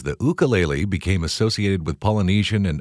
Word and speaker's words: ukulele: I think the most common pronunciation is ukulele, like Y-O-U, but it's actually ukulele.